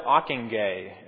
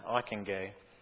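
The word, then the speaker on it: Ockenga: Under the leadership of Harold Ockingay, and that is how you say that, Ockingay, like McIntyre, a former student of Machen, neo-evangelicals also sought ecumenical expression through the founding of the National Association of Evangelicals.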